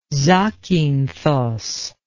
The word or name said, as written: zakynthos